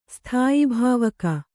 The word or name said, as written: ಸ್ಥಾಯಿಭಾವಕ